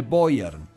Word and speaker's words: Beuern: poemas, cantos, son los cantos de Boyern. – Carmina Burana. – A ver, espérame. ¿Cómo se escribe Boyard? – Boyer, B-E-U-E-R, B-E-U-E-R-N, Beuern, Boyern.